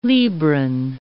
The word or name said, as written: Libran